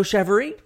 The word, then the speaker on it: Chevarie: is Joe Chevarie.